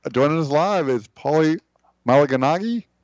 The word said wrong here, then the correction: Malignaggi: In Italian, the GN is pronounced like a Spanish Enya, so it's Malignaggi.